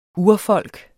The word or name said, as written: urfolk